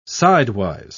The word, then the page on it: sidewise: British English